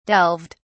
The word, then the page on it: delved: British English